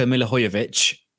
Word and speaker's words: Milivojević: But, I mean, what we can also take heart is that Luka Milivojević, hopefully I pronounced that right. – I think you nailed that. – I think I did.